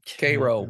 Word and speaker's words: Cairo: If you're Cairo, Cairo. Uh-huh. I know. Well, that's how they pronounce it down there.